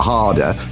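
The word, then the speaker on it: ha det: It's not quite spelled H A R D E R, harder. It's H A R space D E T, but it's pronounced harder.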